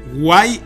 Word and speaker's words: guay: Way o guay, no, perdón, lo estaba pronunciando como inglés, guay en la entrada, ver visiones como entre sueños.